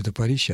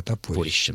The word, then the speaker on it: топорище: И ударение будет другое, топорище. Большой топор — это не топорище, а топорище.